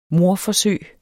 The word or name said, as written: mordforsøg